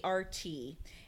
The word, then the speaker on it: Urquhart: Anyway, it's Urquhart.